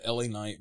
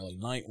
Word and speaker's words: LA Knight: And then we had LA Knight versus Jake Atlas, where LA Knight won. – You didn't say that right. – Sorry. LA Knight.